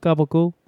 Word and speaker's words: capicola: I bought capicola because I'd watched so much Sopranos, and they pronounce it gabagool.